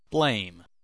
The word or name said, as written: blame